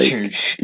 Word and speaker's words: Juche: It's like Juche. Please pronounce it properly. – Sorry, Yushay.